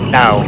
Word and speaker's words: DAO: Actually, pronounced like the Dow Jones Industrial Market for Stocks, DOW. Actually, it's like, imagine putting your tongue on your teeth. Don't say thou, but DAO, like almost like that, DAO.